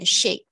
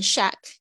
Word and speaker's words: shake: 注意一下你们 shake 的发音，这个呢，呃，中间夹了一个 k，这个 a，呃，k e 非发成 ache 的音，所以它好像嘴巴就是会拉长，然后微笑发出那个声音 shake，ache，shake，所以特别注意一下它的发音。好，milkshake。你念成 shack 的话，就会变成另外一个字，所以这个这是我们这个课程的重点，其实就是纠正你的发音，所以记得一定要教你们的语音作业哈。